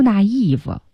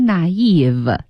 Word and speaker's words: naive: 啊、说你是 too young, too naive。大家知道这 naive 这单词啊、嗯、当然读音要读准了，不要跟网上乱读啊。 – 对。 – 不是什么 naive, 它是 naive。